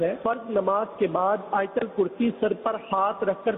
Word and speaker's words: آیت الکرسی: یہ آیت الکرسی عوام بولتے ہیں، صحیح تلفظ ہے آیت الکرسی.